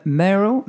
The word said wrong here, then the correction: Merle: Remind me again how to say it again. Merle. Merle.